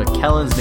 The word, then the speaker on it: McKellen's: And McKellen's name is pronounced like that, not however I pronounce it on the podcast.